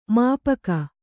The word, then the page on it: ಮಾಪಕ: māpaka